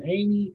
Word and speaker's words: Amey: Amey,